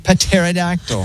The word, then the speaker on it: Pterodactyl: It's spelled with a P. Pterodactyl.